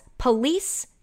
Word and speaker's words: Pulisic: He's from the United States, prefers his surname to be said as Police-Sick.